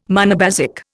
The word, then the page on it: monobasic: Indian